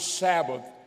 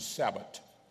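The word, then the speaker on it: Shabbat: So when you get your handout, you understand the word Sabbath means rest. Uh, the, I think the Jews pronounce it Sabbath, Sabbat. They really is the way they do it, Sabbat.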